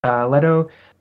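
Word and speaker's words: Lehto: Marcus Leto, am I saying that right? Leto? – Marcus Leto, okay. Yep.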